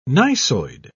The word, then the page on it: gneissoid: British English